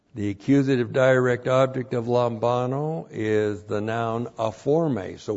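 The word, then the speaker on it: lambano (λαμβάνω): Looks like that in the Greek, L-A-M-B-A-N, long O, lambano.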